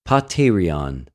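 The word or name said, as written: ποτηριον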